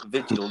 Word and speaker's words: Vigil: Linebacker Nick Virgil. Um, I think that's a bit Vigil, not Virgil.